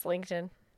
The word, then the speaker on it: LinkedIn: I pronounce it Lindekin, and as I know, it's LinkedIn.